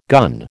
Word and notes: gun: American English

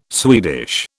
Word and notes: Swedish: British English

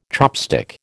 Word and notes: chopstick: American English

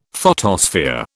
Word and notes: photosphere: British English

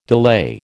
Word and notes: delay: American English

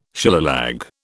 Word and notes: Shillelagh: British English

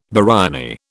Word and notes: biryani: British English

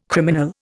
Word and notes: criminal: Indian

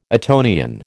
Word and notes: Etonian: American English

